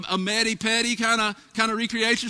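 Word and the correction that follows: mani-pedi: What is that? Did I say that right? A manny petty. What is it? Help me out. I've never had one. Manny Petty. Thank you.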